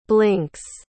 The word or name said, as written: blinks